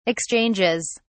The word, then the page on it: exchanges: British English